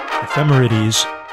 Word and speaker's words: ephemerides: At least today, NASA has ephemerides tables just available on the internet. Ephemerides, E-P-H-E-M-E-R-I-D-E-S.